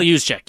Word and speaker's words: Juszczyk: Kyle Juszczyk, yeah.